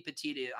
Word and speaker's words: petit: Um, but a petit jury, that's the actual pronounce. And uh, uh, well, actually, it could be either way. Actually, now I think about it, it could be petite. I've always heard it as being petit from from my law professors or what have you.